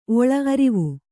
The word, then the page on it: ಒಳ ಅರಿವು: oḷa arivu